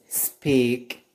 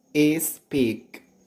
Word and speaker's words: speak: However, today you're gonna learn the pronunciation in the beginning of the word like this: Sunday, sometimes, speak. But careful, many people put a I sound before the S, but there is no I sound before S. It's not is speak.